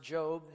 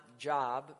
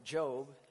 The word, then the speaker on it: Job: Now, for those of you who are not familiar with the book of Job, and it's pronounced Job, not Job, but Job, it is about a righteous and prosperous man who is attacked by the devil.